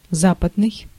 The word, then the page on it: Western: American English; [ˈwɛs.tɚn]